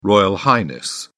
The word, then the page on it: royal highness: British English